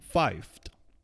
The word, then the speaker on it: pfeift: Ich glaube, mein Schwein pfeift. Mm, pfeift es, un poco difícil de pronunciar para nosotros españoles porque empieza con una P y le sigue una F.